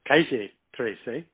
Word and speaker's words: Calle 13: where they pronounce this Calle 13.